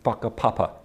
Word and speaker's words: whakapapa: It's a bit like, you know, in Maori culture, there's this concept of whakapapa, spelt with a WH, though pronounced WH is pronounced with an F in Maori.